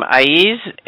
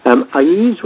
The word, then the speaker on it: Aiz: I pronounce his name Aiz as well, so we're either both wrong or we're both right.